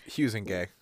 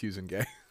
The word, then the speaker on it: Huizenga: They spell it different ways, but it's always Huizenga. – Interesting. Okay, well, we'll say Wayne Huizenga,